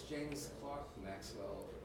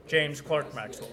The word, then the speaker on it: James Clerk Maxwell: Also, I think just like a clerk in a store in England is pronounced a clerk, it's James Clerk Maxwell. – James Clerk Maxwell, yeah, yes.